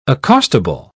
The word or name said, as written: accostable